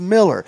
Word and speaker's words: Moeller: M-O-E-L-L-E-R, by the way. You spell it. It's not Moeller, it's Miller.